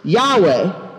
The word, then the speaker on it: Yahweh: That's actually a holding place for the Tetragrammaton, which we pronounce Yahweh, or in older translations, Jehovah.